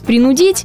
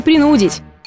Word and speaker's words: принудить: Несмотря на то, что практически повсеместно можно услышать «принудить», все же единственно верным ударением является «принудить». Принудить знакомого написать жалобу, принудить подругу обратиться в суд или же принудить сына выполнить домашнее задание по математике. Всегда, везде и во всех случаях без исключения ударение будет приходиться на «у».